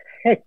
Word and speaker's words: hex: Es curiosa la pronunciación en inglés. Le dicen hex.